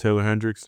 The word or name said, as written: Taylor Hendricks